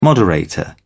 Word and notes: moderator: British English

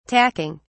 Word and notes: tacking: British English

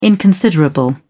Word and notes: inconsiderable: British English

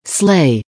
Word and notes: slay: American English

